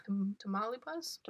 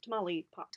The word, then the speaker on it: Tamaulipas: Tamaulipas? – Tamaulipas? – Tamaulipas? – Tamaulipas. – Tamaulipas.